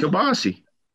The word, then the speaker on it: kielbasa: Kielbasa? Kielbasa. – Is that how you say it? – Yeah, a lot of people say it like a moron.